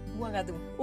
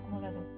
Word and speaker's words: Ouagadou: Uagadú. – Uagadú. Uagadú. – Okay. Empieza con U. Uagadú.